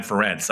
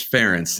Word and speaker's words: Ferentz: Yeah, I'm excited to see Manny Diaz versus Brian Ferentz. I don't know about you. That should be pretty good. We'll see. You're you're mispronouncing again. It's Ferentz, and and you know you're doing this.